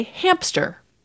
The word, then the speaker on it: hamster: And you said it the way it's spelled. I, when I say this word, typically say hamster. – Like a hamper? – But there's a phonological reason when you go from the M to the S that you could get a P sound.